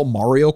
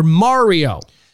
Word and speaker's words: Mario: Mario or Mario? – See, here's the thing. If you're Italian, you usually pronounce you almost always pronounce an A. Mario.